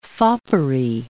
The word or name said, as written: foppery